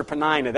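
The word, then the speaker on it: Peninnah: I'm going to pronounce her Penina. That's the way I grew up pronouncing her.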